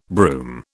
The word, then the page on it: broom: British English